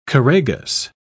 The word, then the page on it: choragus: British English